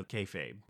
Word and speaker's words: kayfabe: So Kayfabe, did I say that correctly? – You did.